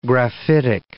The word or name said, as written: graphitic